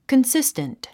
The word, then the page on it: consistent: kənsístənt; コンシステント